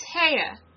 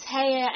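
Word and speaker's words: Haigha: His name is Haya." – He pronounced it so as to rhyme with mare.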